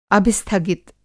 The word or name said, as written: अभिस्थगित